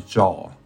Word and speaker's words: Zhao: So my first name is pronounced as Xian, and my last name is Zhao.